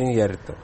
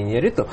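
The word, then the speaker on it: Iñárritu: Правильно говорится, это Иньярриту, а не Иньярриту.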